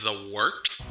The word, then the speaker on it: Wort: It is The Wort, not warts, not forts, not short, The Wort.